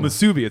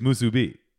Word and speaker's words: musubi: So it's really three syllables. It's not musubi, it's musubi.